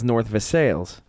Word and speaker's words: North Versailles: Everywhere else in the world, it's pronounced Versailles. Yeah. Here. – Them's the French. – Right. Here in Pittsburgh, it is North Versailles.